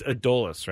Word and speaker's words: Adolis: Adolis, I pronounce this guy's name wrong all the time. It's Adolis, right? – Yes.